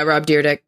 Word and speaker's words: Rob Dyrdek: And then Rob Dyrdek, isn't that how you say it? – Yeah, Rob Dyrdek.